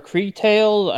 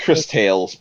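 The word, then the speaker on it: Cris Tales: Chris Tales or Cree Tales? I don't know how to pronounce it. – Chris Tales, because Crystal.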